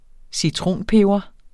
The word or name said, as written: citronpeber